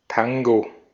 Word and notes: Tango: IPA: /ˈtaŋɡo/

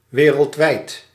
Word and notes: wereldwijd: /ʋe.ɾǝld.ʋɛjd/

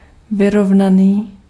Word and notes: uniforme: /y.ni.fɔʁm/

